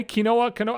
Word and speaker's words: quinoa: How do you say this? Q-U-I-N-O-A? Quinoa? I don't give a shit. I don't want to know what that word is. You know what the word is. You see it. Quinoa.